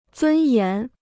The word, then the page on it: 尊严: zūn yán